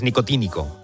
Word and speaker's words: nicotínico: ¿El receptor nicótico de acetilcolina? – ¡Wow! – Claro. – ¡Oh, pero si perdona! – Bueno, lo he dicho mal, es nicotínico.